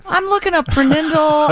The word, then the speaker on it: PRNDL: And the Prenindle, the P-R-N-D-L, right above the automatic shifter, she called the Prenindle.